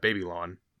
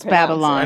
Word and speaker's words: Babylon: They're gonna go to the hippie commune, Baby Lawn, or is that how they pronounce it? – Baby that's Lawn. Only how Madison. – Well, it's Babylon. – I know it's Babylon, but I know they do it wrong.